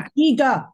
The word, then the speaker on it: Antigua: Antigua.